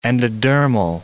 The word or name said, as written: endodermal